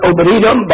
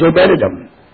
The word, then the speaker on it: Obed-Edom: Some have pronounced it Obed-Edom, but the correct pronunciation after looking it up again is Obed-Edom.